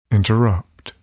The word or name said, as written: interrupt